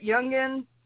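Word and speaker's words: Youngkin: Am I saying that right, Youngin? – I believe so.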